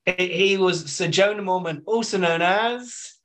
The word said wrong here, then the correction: Jorah Mormont: I'm sorry, he was Sir Jorah Mormont.